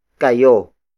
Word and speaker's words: kayo: Bukas is spelled as B-U-K-A-S. It means tomorrow. Again, that's tayo. The next pronoun is kayo. Kayo. Kayo. Kayo is spelled as K-A-Y-O.